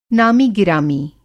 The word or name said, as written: नामी-गिरामी